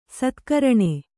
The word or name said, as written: ಸತ್ಕರಣೆ